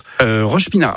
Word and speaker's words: Roche-Pinard: Vous changez de quartier, vous allez à Roche-Pinard. Alors, c'est bien comme ça qu'on prononce. – Roche-Pinard.